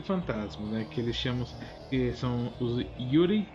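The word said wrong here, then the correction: yūrei: Yurei, né? Acho que é pronunciar é assim, Yurei.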